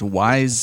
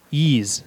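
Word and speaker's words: Ys: How do you fucking pronounce this? Wise 6? The Ark of... – It's Ease.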